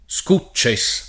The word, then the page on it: Scucces: italiana